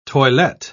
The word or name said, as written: toilette